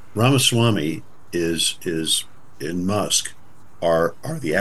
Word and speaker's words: Ramaswamy: And Ram, did we ever figure out how to pronounce Vivek's name? Ramaswamy. – Yeah, Is that right? – I thought I did a couple times.